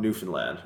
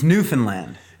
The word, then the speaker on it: Newfoundland: But, like, so I'm from Newfoundland originally.